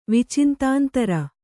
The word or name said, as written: ವಿಚಿಂತಾಂತರ